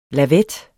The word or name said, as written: lavet